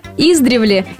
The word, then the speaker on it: издревле: Оказывается, единственно верным нормативным ударением является ударение, падающее на второй слог. Издревле. Да, друзья, издревле, издревле, еще раз издревле. А широко распространенное в повседневной речи ударение на первый слог, издревле, является речевой ошибкой.